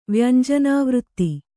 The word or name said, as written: ವ್ಯಂಜನಾವೃತ್ತಿ